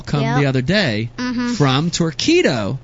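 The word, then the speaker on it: Torqeedo: Torquedo? – Torquedo. That's right. You said it right.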